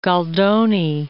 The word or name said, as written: Goldoni